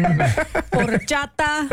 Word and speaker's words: Horchata: You did great, except your pronunciation of Spanish words is lacking. Horchata. – Horchata.